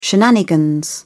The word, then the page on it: shenanigans: British English